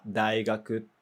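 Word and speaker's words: daigaku (大学): Daigaku te yomimasu yo. Yomimasu, se lê. Então, olha, então isso aí também, esse kanji que você viu também se lê. Daigaku, a pronúncia dele é daigaku.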